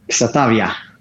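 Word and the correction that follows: Xàtiva: ja, tako da eh, tudi jaz sem najprej mislil, da je ksativa ali pa kot španci včasih ki x v nekaterih pokrajinah izgovorijo kot h, v nekaterih kot š ampak ker je to v tej isti pokrajini, kjer so dirkali danes v Valencijani, je potem šativa.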